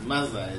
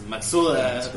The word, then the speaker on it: Mazda: O sea, y si vas a pronunciar bien, pronuncia bien Mazda, cabrón, que no es Mazda, es Maxuda.